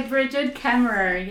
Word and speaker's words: Brigid Kemmerer: Kemmerer. – By Bridget Kemmerer, yes.